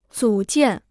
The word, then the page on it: 组建: zǔ jiàn